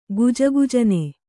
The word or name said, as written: ಗುಜುಗುಜನೆ